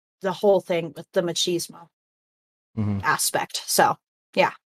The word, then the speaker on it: machismo: How do you say that machismo. – Word? Machismo.